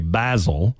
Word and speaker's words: basil: Number one, basil. Or possibly, if you're pronouncing it the British way, basil.